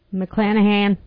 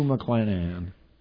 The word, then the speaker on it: McClanahan: Here is Rue McClain. – McClanahan. – McClanahan? Is that how it's pronounced? – Yes. – Rue McClanahan.